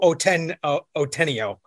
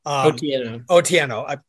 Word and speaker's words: Otieno: It's a story we've been talking about for days now on the podcast and our newscast, the death of Irvo Otieno. – Otieno.